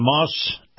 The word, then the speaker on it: Maus: Moss is spelled M-A-U-S, Acura.